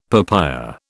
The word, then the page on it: papaya: British English